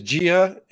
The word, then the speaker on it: Jia: So your company is Gia. Is that pronounced correctly? – That's right.